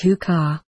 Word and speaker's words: Huka: The word here is Huka, and it is pronounced Huka.